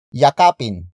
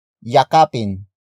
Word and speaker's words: yakapin: Yakapin mo siya. Yakapin mo siya. Yakapin mo siya. It means embrace that person. The focus is on that person. Word for word it's yakapin mo siya. Yakapin mo siya.